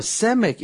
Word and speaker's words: Samech: Now, Samech in the original Hebrew, it's pronounced like the S sound.